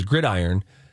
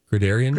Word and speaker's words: gridiron: You know, people refer to a football field as gridiron, and she told me during the break, how did you pronounce it? – I think I said gridiron. – Gridarian? – Gridarian or something like that. Added like an extra I.